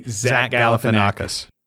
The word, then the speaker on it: Zach Galifianakis: Zach Galifianakis.